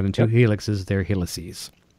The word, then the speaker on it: helices: We've got two Helix. Okay, what's the plural? Heli? Helixi? How do you say Helices. – Helices. Okay.